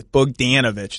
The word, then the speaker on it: Bogdanović: You're not Simmons, who's going to call him Bogdanović, but we got to get the "an" out of you. Bogdanović. It's Bogdanović.